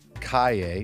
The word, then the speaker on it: Calle: Kalle, which is spelled C-A-L-L-E, is a street soccer brand and nonprofit organization.